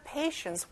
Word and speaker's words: patient: 자, patient는 환자란 뜻이고요. T 발음이 안 나요. 자, 한번 해볼게요. The patients were waiting in line.